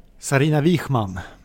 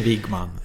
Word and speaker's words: Sarina Wiegman: hjälp mig med uttalet på den engelska förbundskaptenen. – Sarina Wiegman. – Wiegman. – Okej, inte Wiegman.